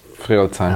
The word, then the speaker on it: Friedrichshain: So your family comes from this region, from Fields? – Friedrichshain. – Ah, sorry, my pronunciation is not the best.